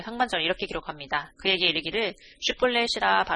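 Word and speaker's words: 쉿볼렛이라 (שִׁבֹּלֶת): 에브라임 사람들이 그렇게 바로 말하지 못하고 시뿔렛이라고 발음하면 에브라임 사람들은 쉬를 발음할 수 없었습니다.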